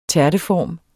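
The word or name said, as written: tærteform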